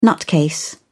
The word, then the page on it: nutcase: British English